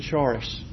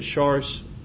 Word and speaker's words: charis: The word grace, if you follow the Greek definitions back to charis, I can't quite say it right, but charis, C-H-A-R-I-S in our spelling.